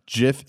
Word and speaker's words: GIF: I will fully admit that JIF is the correct term. The guy who invented it has said it is JIF. It's a hard J sound.